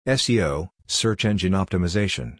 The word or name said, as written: SEO (Search Engine Optimization)